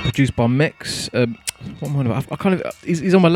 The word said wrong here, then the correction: Mesk: label and I've got his name wrong. Mesk, not Mex.